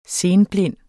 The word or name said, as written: senblind